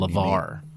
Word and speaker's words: LeVar: Lamar Burton. – Yes. – Mm-hmm. – From Star Trek. – Is it Lamar or LeVar? – LeVar with yeah.